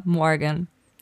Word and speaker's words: Morgan: Also tatsächlich, von mir aus könnte es auch deutsch aussprechen, aber richtig ist es äh, Morgan. Maya Morgan. mit diesem super amerikanischen Morgan.